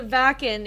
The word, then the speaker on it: Wacken: It's the Wacken.